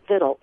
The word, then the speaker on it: victuals: So they decided to change it, and they spelled it V-I-C-T-U-A-L-S, but continued to pronounce it Vittles.